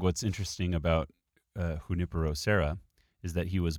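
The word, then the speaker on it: Junípero: Saint Junipero, Junipero. Sorry, there is an accent on the I. I failed to notice that.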